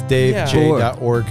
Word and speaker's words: Dave Jorg: You called him Jorg or Gorg? – J. – It is Jorg.